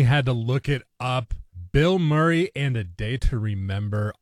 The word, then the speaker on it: Bilmuri: And yes, it's pronounced Bill Murray like the Bill Murray. It's spelled B-I-L-M-U-R-I, but it is pronounced exactly like Bill Murray.